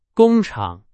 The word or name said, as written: 工厂